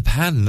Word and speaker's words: pain: Well, it's all about the pan, isn't it? Yes. Which is spelled pain. Yes, P-A-I-N. But it's pronounced pan.